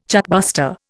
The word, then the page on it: chartbuster: Indian